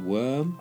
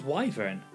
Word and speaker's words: Wyvern: Is it Worm? – I thought it was Wyvern. – But it's Wyvern or Wyvern, isn't it? It's not Worm. It's never Worm. – There is a creature worm called Worm. It's W-Y-R-M.